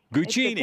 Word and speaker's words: Guccini: So how do you say that exactly? – Guccini. Italian.